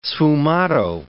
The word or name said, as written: sfumato